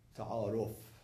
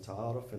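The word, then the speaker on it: ta'aruf (تعارف): Courtesy in Farsi, ta'aruf. Ta'aruf in Arabic is different than ta'aruf in Farsi. Ta'aruf in Arabic, it means to recognize. Ta'aruf in Farsi, I'm pronouncing it the Farsi way, ta'aruf, it means courtesy.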